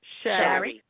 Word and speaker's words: Shari: Hello, Shari, right? Did I say it right? – Shari.